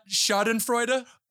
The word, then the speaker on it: Schadenfreude: Is that not Schadenfreude? – They made it out unscathed.